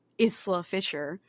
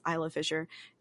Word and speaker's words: Isla Fisher: And then also I was trying to tell the other girl, Bobby, the one that yelled at me about Ilsa Fisher. It's Isla Fisher.